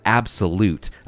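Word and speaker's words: Abxylute: Now, another new player on the scene here, we have Absolute. Apparently, it's pronounced like that.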